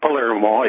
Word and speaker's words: Palermo: Not Palermo, it's Palermo in Italy, but in North Dakota, it's pronounced Palermo, and you would get laughed out of the place if you went and said that.